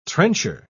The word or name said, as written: trencher